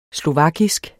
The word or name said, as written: slovakisk